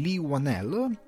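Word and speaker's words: Whannell: infine voglio parlarvi di The Invisible Man di Lee Wanell, stando alla pronuncia che ho sentito nelle interviste, eh, che è un film molto molto interessante che sono riuscito a vedere al cinema giusto giusto ieri.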